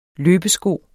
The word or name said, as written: løbesko